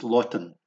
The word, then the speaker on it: lodan: Lotan, and that's spelt L-O-D-A-N, but pronounced lotan.